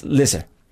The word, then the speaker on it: Leicester: It's pronounced Lisa.